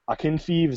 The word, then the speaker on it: Akinfeev: Akinfeev, is that how you say it? – I think so. Yeah. Sounds right.